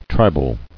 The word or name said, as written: Tribal